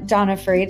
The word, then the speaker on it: Donofree: Dana Donofree, did I say that right? – You did, you nailed it.